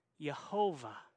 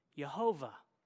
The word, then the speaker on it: Jehovah: A side note here, the Germans in the Reformation, they sound their J as a Y and they sound their W as a V. So when they read this, they came up with Jehovah. But since we're redneck English speakers, we pronounce the J and we get the name Jehovah.